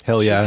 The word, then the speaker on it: Hallea: H-A-L-L-E-A. But hell yeah, she's having Jones.